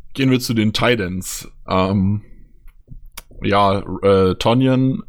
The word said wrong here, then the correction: Tonyan: Tanyan spricht man ihn ja aus, Entschuldigung, ähm, mhm.